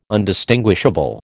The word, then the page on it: Undistinguishable: American English